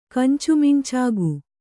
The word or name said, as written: ಕಂಚುಮಿಂಚಾಗು